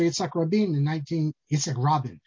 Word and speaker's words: Rabin: By the way, growing up, I always thought it was Rabin. And then I come to Israel. Everyone pronounces it Rabin. I'm not sure. Why isn't it Rabin? Isn't that the way Israelis are supposed to pronounce accent on the second half? Rabin is like the old Ashkenazic way of reading it. Rabin, Perez.